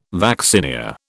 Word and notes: vaccinia: British English

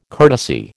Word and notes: courtesy: American English

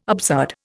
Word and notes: absurd: Indian

